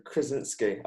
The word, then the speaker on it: Krasinski: Krasin- yeah, yeah, Krasinski.